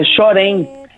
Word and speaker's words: Chorém: Me ajuda com a pronúncia do título dessa música. É Chorém? É Chorém.